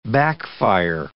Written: backfire

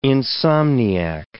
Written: insomniac

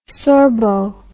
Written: sorbo

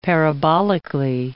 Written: parabolically

